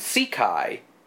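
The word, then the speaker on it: Székely: Balderston spells Countess Sekeski S-Z-E-K-E-L-S-K-Y, and Sekai is spelled S-Z-E-K-E-L-Y.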